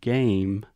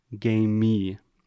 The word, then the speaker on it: game: Also, we started with the word game. Many of our students have the tendency to say game me and add this extra sound.